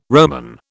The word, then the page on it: Roman: British English